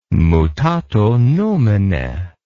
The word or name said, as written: mutato nomine